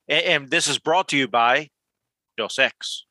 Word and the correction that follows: Dos Equis: H- how do you say that, Karl? Am I saying that right? – Dos Equis.